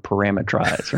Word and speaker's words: parameterize: Do you say it the British way then? Parameterize. Parameterize. – I try to leave out the tur and I just say tries.